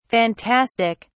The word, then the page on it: phantastic: British English